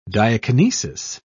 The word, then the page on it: diakinesis: British English